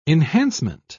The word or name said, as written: enhancement